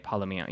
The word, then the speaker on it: Palomino: And then the other one is Palomino. Did I say that right? Palomino. Yeah.